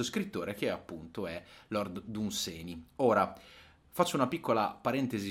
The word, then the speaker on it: Dunsany: Eh, il nome corretto sarebbe Danseni, alcuni lo pronunciano Danseni. È, una, è una, un'americanizzazione del, del, suo nome.